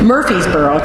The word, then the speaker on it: Murfreesboro: The John Hardison Red family moved from coastal North Carolina to a plantation near Murfreesboro, I can never say this right, Murfreesboro, Tennessee.